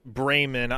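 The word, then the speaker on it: Braman: Very familiar with Brahman, I believe is the correct pronunciation, as far as I am aware.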